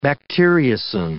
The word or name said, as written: bacteriocin